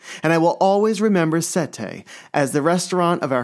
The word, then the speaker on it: sette: Sette.